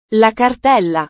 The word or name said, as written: la cartella